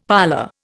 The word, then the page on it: parlour: Indian